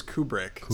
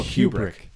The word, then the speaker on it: Kubrick: Is that how you say it? I thought it was Kubrick. – Stanley Kubrick? – I go Kubrick. Like Cool Whip. – Yeah, Cool Whip, yes. Rick.